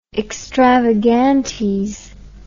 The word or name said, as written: extravagantes